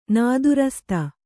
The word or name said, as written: ನಾದುರಸ್ತ